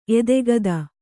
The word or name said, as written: ಎದೆಗತ್ತಿ